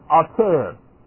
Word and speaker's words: Altair: Now, the brightest star in Aquila, boy, I think, I hate to tell you this, but I can't read that, is Altair.